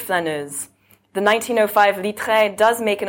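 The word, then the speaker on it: flâneuse: the flaneuse, and what that means and what I'm doing with it, and, you know, we'll sort of take it from there.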